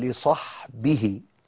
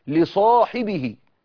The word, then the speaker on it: لصاحبه: الله، رغم ان هي الكتابة صحبه، فلو نطقناها على اللي مكتوبة نقوله اذ يقول لصحبه، انما احنا بنقوله ايه؟ رغم ان هي مكتوبة صاد ح به على طول، بنقوله لصاحبه. فالالف اللي بعد الصاد مش مكتوبة ولكن منطوقة، تنطق لا تكتب،